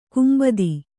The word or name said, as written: ಕಾಂಬೋದಿ